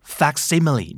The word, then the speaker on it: facsimile: facsimile